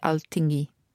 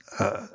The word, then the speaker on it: Alþingi: How do you pronounce it? Do you know? – Alþing. – I should know this. It's Alþing. Yeah. – Alþing. And I felt very, very guilty because I stayed at Christmas and I had reindeer steak on Christmas Day.